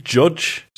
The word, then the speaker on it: Jydge: I've been playing Judge, spelt with a Y, so it looks like Jidge.